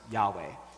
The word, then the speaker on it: Yahweh: So there's actually four Hebrew consonants there, YHWH, which scholars generally agree now is supposed to be pronounced Yahweh, okay? But it seems like they don't actually know for sure,